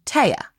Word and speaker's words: Tea: Right, spelled T E A, but not pronounced T, obviously, pronounced Taya.